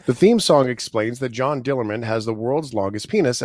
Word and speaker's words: John Dillermand: It's called John Dillermand.